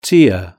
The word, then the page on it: tear: British English